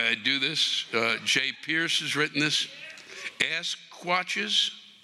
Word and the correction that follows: Sasquatch: Sasquatch, probably.